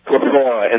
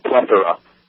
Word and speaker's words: plethora: Just one quick one before I go is, one of you, I think Mark or Ian, I can't remember, said plethora, and the word is plethora.